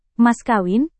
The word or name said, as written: mas kawin